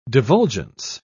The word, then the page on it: divulgence: British English